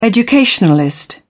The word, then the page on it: educationalist: British English